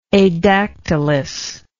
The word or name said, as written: adactylous